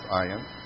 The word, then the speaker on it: Ayin: It's silent, like Aleph. It's not usually spoken, but it's the letter itself is pronounced Ayin. Then you have Pei, P-E-I.